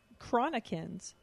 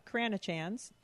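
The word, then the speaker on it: cranachan: We were her little chronicans sent in by Abby on Patreon. Allison did not say it correctly and did not look it up. I did. It's not Cranachans. It's Chronican.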